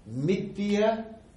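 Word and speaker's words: mithyā: And what he did was, he took this word, mithya jnana, false knowledge. You see, the word mithya actually is a long A at the end. Two A's, actually. It's better.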